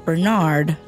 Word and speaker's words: Bernarr: And no, by the way, we are not mispronouncing his name. Bernarr MacFadden is born Bernarr MacFadden and changes his name as an adult because Bernarr sounds like a lion's roar.